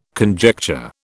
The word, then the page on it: conjecture: British English